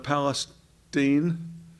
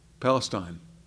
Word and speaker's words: Palestine: It doesn't matter if you're living in ancient Palestine. Did I say that right? No? Palestine. Thank you.